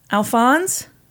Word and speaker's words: Alphonse: It is spelled A-L-P-H-O-N-S-E. Alphonse?